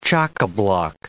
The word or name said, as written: chockablock